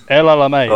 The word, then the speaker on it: El Alamein: How do you pronounce that? – El Alamein, isn't it? – El Alamein. – Oh, El Alamein, right, okay.